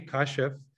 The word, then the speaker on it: Kashif: So the funny thing is it's actually Kashif. But I didn't know that until I was like 18 because I got called Kashif growing up by everybody.